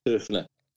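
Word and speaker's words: Höfner: Everybody says Hofner. I don't know. I've never heard anything. Right or wrong. – I was a bona fide German bloke that it's actually pronounced Hofner.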